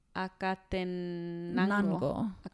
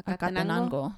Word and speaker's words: Acatenango: How do you pronounce the C? – Just the normal C. Volcan Acatenango. – Acatenango.